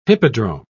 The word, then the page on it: hippodrome: British English